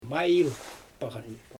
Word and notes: majɪjl paɣan: Xyzyl

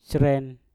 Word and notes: sren: mpur

